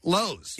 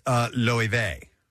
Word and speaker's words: Loewe: Louis, or at a quick glance, I would say Lowe's. – Yeah. – Lowe's, that's what it looks like. – The hardware store. – Oh, it's, okay, it's Spanish. Spanish luxury fashion house. – It's pronounced Loewe.